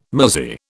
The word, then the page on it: muzzy: British English